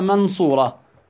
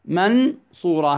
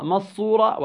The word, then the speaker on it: من صورة: كيف ننطق؟ ننطقها من صورة؟ لا، هيك إظهار، ننطقها مصورة، وهذا أيضا ماذا؟ إدغام، لا يصح. ننطقها في حالة بين الإدغام والإظهار ونقول ماذا؟ منصورة، منصورة.